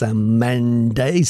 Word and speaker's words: Cymande: That's Simande. They are a British funk group. They was really active in the early 70s in England. And the name Simande, pronounced Samande, C-Y-M-A-N-D-E, derives from the Calypso word for dove, which symbolises peace and love.